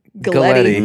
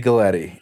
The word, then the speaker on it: Galletti: I'd say, think spaghetti Galletti. It rhymes. And then I was called Elder Spaghetti from there on out.